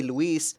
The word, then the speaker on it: Luis: Pronunciation is important for me, because people, right, growing up in that Valley View district, I remember that they started started calling me Lewis, and I'm like, that's not my name. And no one could or would say Luis.